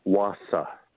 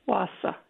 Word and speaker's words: Wausau: I would say Wausau. – Wausau, yeah.